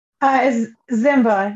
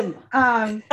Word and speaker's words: Ziemba: Ziemba, you're close. Ziemba. My dad says Ziemba.